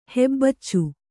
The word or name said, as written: ಹೆಬ್ಬಚ್ಚು